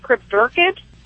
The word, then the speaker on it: cryptorchid: He was, am I saying it right, cryptorchid? – Oh, yeah.